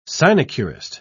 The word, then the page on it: sinecurist: British English